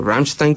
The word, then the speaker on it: Rammstein: Y pues no sé, en la tercera banda estoy debatiéndome entre Rammstein, o Rammstein como lo pronuncian ellos, y Foo Fighters, yo creo que una de las dos.